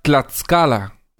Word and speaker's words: Tlaxcala: Cintia Berenice dice que se pronuncia Tlaxcala.